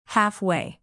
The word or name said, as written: halfway